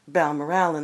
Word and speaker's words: Balmoral: It's also the inspiration for our very own 19th-century Balmoral Hotel, often pronounced Balmoral in these parts, a place that's seen its own share of successful hunters over the last 125 years.